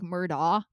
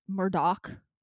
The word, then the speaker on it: Murdaugh: I feel like the GH needs to be silent, like Murdaugh, but then everyone that says it says Murdoch.